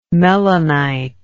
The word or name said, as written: melinite